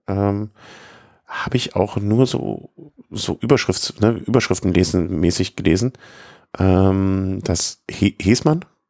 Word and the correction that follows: Hessmann: Hessmann spricht man es aus, okay.